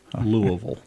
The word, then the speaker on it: Louisville: It's L-O-O hyphen A hyphen V-U-L, Louisville. – Louisville.